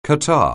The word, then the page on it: catarrh: anglais